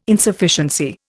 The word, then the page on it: Insufficiency: Indian